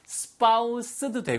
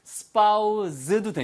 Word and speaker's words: spouse: spouse는 발음이 두 가지예요. spouse도 되고요. spouse도 됩니다. 아시겠죠? spouse 아니면 spouse. 다 가능하니까 알아두시고요.